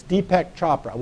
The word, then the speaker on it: Deepak Chopra: Is that Deepak Chopra? – Deepak. I got Deepak. Okay, it's Deepak Chopra.